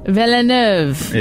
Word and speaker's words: Jacques Villeneuve: J A C Q U E S. Jacques. Jacques. Jacques. Jacques. Notros. Jacques Villeneuve. Villeneuve. – It's pronounced the same way as Denis Villeneuve.